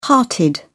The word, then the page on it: hearted: British English